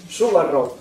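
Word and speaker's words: Subba Rao: Se pronuncia Rao, gracias. Subarrao, vamos a decirlo con la pronunciación.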